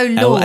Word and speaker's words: Laud: 2 years later, The Race for Space used similar methods to how do you say that word? Lord. – Is it Loud? Lord? Yeah, I go Lord. – L-A-U-D.